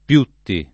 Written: Piutti

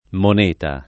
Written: moneta

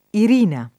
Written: Irina

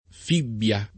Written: fibbia

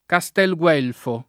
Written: Castel Guelfo

